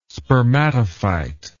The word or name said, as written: spermatophyte